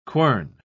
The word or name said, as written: quern